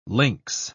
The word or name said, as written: lynx